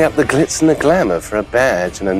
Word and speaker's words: glamour: Po brytyjsku wymówimy to słowo jako glamour, a Amerykanin powie glamour.